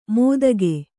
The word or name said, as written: ಮೋದಗೆ